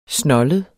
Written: snoldet

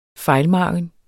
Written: FEJLMARGENERNES

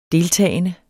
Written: deltagende

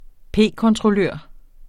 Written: p-kontrollør

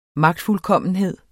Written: magtfuldkommenhed

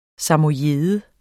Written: samojede

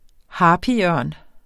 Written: harpyørn

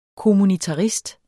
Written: kommunitarist

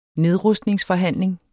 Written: nedrustningsforhandling

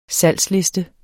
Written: salgsliste

